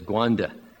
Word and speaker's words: Rwanda: In the news recently, you know there's been a great killing going on in Rwanda, which the locals pronounce as Gwanda.